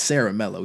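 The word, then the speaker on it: Ciaramella: CJ Saramella, I finally figured out how to pronounce this guy's name, by the way. I just went ahead and tweeted him and asked him how to do it. So it's Saramella.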